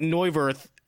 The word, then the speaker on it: Neuvirth: It just seemed like Neuvirth was Neuvirth. – You don't pronounce the H.